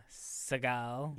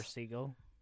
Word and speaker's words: Seagal: Starring Steven Seagal. – Yes. – Or Seagal, if you want to pronounce it wrong.